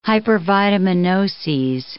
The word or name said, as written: hypervitaminoses